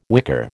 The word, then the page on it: wicker: American English